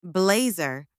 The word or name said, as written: blazer